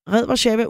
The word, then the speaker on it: Red Warszawa: Red Warszawa udtaler deres eget navn som Red.